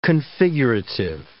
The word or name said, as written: configurative